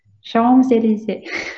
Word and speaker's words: Champs-Élysées: something like Champs-Élysées. Did I say it right? – Champs-Élysées. – Champs, yeah. You can't say the P.